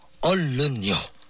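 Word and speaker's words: Ollon (얼른): Alors, tout d'abord, quelques mots sur la prononciation. Il y a deux « liol ». Il faut donc bien prononcer en détachant chaque syllabe. « Ollon » et non pas « ollon », qui veut dire un adulte, une grande personne.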